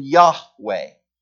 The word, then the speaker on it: Yahweh: And all we know is four consonants, YHWH. And the two popular ways of pronouncing it is Jehovah and Yahweh.